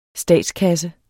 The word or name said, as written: statskasse